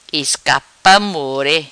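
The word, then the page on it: Iscapa Morre: Patuá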